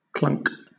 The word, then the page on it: clunk: British English